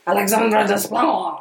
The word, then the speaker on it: Alexandre Desplat: Yeah, Alexander Desplat? – Yeah. – I'm sure there's a cooler way to pronounce that, and I'm butchering it. – But... Desplat. – Alexandre Desplat.